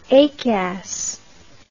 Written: acas

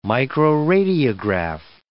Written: microradiograph

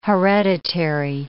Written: hereditary

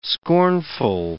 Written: scornful

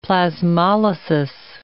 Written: plasmolysis

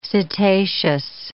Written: cetaceous

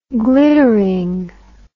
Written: glittering

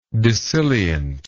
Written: dissilient

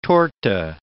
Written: torte

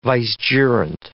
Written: vicegerent

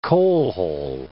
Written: coalhole

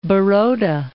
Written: Baroda

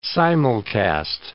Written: simulcast

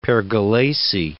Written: Pergolesi

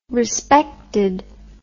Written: respected

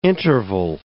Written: intervale

